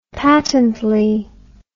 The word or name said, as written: patently